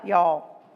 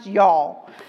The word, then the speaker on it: Youghal: It's Youghal. I'm not being Texan here. It's pronounced Youghal, and it's in the southeastern coastline of Ireland.